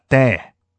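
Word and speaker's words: day (δέ): 一章六节，只要这两个字其实希腊文读作 day，英文翻译作 but，中文解释作但是。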